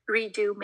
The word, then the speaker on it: Redux: It's called Redo Makeup, and you would spell it as R-E-D-U-X Makeup.